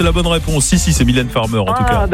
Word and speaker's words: Mylène Farmer: Mineur Farmer. Je prononce mal. – Alors, prononcez-le moi bien, sinon je ne peux pas l'accorder. – Mylène Farmer.